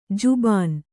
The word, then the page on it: ಜಬಾನಿ: Kannada